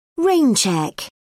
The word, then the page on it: rain check: British English